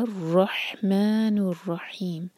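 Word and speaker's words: रहमानुर रहीम (الرحمن الرحيم): रॉ से पहले जो लाम है ये लाम शमसिया है तो इसको प्रोनाउंस नहीं किया जाएगा. हुआ रहमानुर रहीम और वर्ड रहमान और रहीम दोनों में हा क्लियर.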